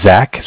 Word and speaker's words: Zach: So my name is Zach, spelled Z-A-C-H. – Okay. – I'm from Bank of Cardiff, and Cardiff is spelled C-A-R, like car, D as in dog, I-F-F, as in Frank Frank.